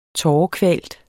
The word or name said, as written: tårekvalt